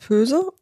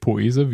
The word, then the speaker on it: Poese: Genau. Aileen heißt Pöse. – Nicht Poese, wie manche Mutter. – Wie eigentlich regelmäßig behauptet wird.